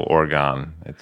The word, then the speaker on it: Oregon: And then there's no Oregon. – Oregon, yeah, like O-R-Y-G-U-N, that kind of spelling. It helps phonetically.